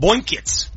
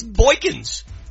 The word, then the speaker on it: Boykins: You just called him Boykins. – Boykins. – It's Boykins. – Boykins. There we go.